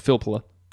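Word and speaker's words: Filppula: I always say his name wrong. – Like Philpula? – How is it? – It's Philpula. – Oh, I did it right. – Yeah, that's right.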